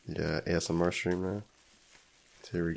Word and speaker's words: ASMR: You're not saying it right. – My asthma. – You're saying AMSR.